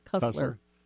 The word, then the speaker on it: Cussler: And so the shipwreck stuff, the Titanic, how you and I met, the information on your book, Clive Cussler, did I pronounce his Cussler. – Name right? – Cussler.